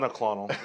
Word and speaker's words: monoclonal: Even today, so Ron DeSantis was in Tampa talking about this and talking about the Medicorian facilities that they have here in the state. Said that wrong. Monoclonal. – Monoclonal. – I'm purposely saying that.